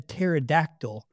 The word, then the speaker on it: pterodactyl: Why can't you hear a pterodactyl going to the bathroom? – I don't know. – Because the P is silent.